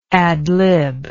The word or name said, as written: adlibber